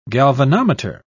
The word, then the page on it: galvanometer: British English